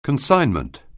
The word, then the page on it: consignment: anglais